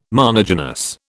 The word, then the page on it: monogynous: British English